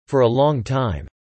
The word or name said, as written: for a long time